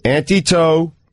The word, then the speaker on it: Antetokounmpo: Giannis Antetokounmpo. Nailed it. – Stugats. – Giannis. – You got that part. You're locked in on that part. – Antetokounmpo.